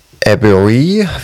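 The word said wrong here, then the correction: Edebiri: Wie wird sie ausgesprochen? – Ayo Edebri? – Edebri, ja, genau.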